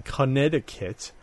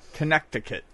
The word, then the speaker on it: Connecticut: Retro World, the 15th and 16th, and that's in Connecticut. Can never spell that. It's like Connie cut. Connecticut. Connecticut. There's an extra syllable in there.